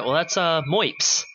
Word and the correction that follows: MIOPS: Or how it should probably be pronounced. – MIOPS, yeah. MIOPS. Pretty sure it's MIOPS.